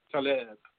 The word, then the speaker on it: Taleb: In fact, two things, but one thing, and both of them are from Nicholas Taleb. That's the way they say his name, T-A-L-E-B, Nicholas Taleb.